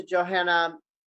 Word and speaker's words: Johanna: Reach out to Johanna.